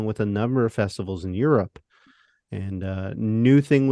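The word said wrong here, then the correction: Nu Thang: was released on... – You said it wrong. You can't say it that way. – Yeah. Sorry. New Thang was released in September 1990.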